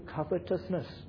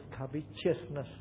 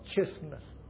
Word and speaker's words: covetousness: Then it will lead to covetousness. Some pronounce it covetousness, but I believe I'm correct here. I check up the dictionaries. Covetousness, not chiousness. There's no I there, not T-I-O-U-S, but T-O-U.